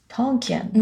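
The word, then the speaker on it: Tonkean: 今日はですね、トンキアンという会社です。 – ちょっと待って、もう一度言ってください。 – トンキアン。 – トンキアン。 – T-O-N、トン。 – うん。トン。 – K-E-A-N で、キーンですね。 K-E-A-N って書いて、キアンっていう風に発音するみたいです。はい、トンキアン。 – トンキアン。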